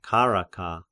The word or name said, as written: Charaka